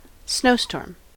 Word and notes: snowstorm: American English

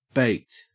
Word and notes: baked: Australian English

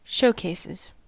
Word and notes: showcases: American English

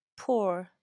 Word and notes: poor: American English

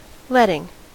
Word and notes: letting: American English

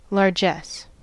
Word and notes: largess: American English

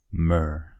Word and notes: myrrh: American English

